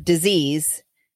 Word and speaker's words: dis-ease: You pronounce the word disease, dis-ease.